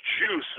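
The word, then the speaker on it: Guice: Darius, I believe it's pronounced Juice, is a running back out of LSU that's going into the NFL draft this year.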